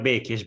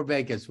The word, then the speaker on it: Békés: Gabor Bacchus. I said it wrong. How do I say it? It's Gabor Bekes, but that was close enough. Gabor Bekes with with Gabor Kezdi uh,